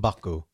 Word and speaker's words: M'Baku: M'Baku.